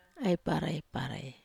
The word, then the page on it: ai parai: Wamesa